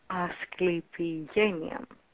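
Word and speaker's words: Asclepigenia: I'll say it one more time, the Greek way, Asclepienia.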